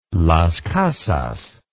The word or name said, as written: Las Casas